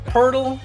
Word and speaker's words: Poeltl: Poeltl rhymes with turtle.